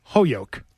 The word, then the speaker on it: Holyoke: Oh, and by the way, it's Holyoke. That's how they say it's Holyoke.